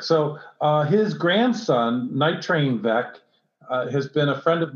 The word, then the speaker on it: Veeck: And one of the names on your ownership list and team setup is it rhymes with wreck. And we know Bill Veeck is one of your all-time heroes.